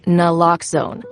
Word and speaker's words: Naloxone: For the record, it's Naloxone. Nuh, like, is Naloxone addictive? Nuh. Lox, like Naloxone keeps humans safe, like locks keep bikes safe. And own, like Naloxone can save lives in our very own communities.